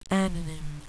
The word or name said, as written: anonym